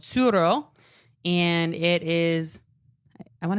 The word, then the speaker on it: Tsuro: You're going to have to spell it because I thought you said churro at first. And I was like, I love churros. Where are we getting one? – It's spelled T-S-U-R-O.